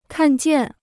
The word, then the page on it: 看见: kàn jiàn